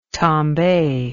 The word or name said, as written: tombe